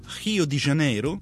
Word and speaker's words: Rio de Janeiro: Ringraziamo Andrea Ciacchi che ci ha accompagnato in questo viaggio alla scoperta di Rio de Janeiro, l'ho detto bene? – Benissimo.